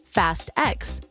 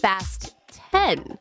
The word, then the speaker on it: Fast X: And a lot of people say Fast X, but the director says no, it's pronounced Fast 10, as in fasten your seatbelts.